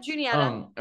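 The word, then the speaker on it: Juniata: Junietta, am I saying it right? – Yep, Junietta.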